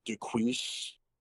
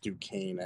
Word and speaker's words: Duquesne: You know, we have, you know, Duquise. just for funny purposes, Marcus pronounced Duquesne as Duquise.